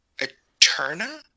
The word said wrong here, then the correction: Eternia: It's pronounced Eternia, but yes, it is.